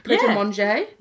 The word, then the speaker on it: Pret a Manger: Is that how you say it? Pret a Manger? – Yeah. Oh, you're doing great.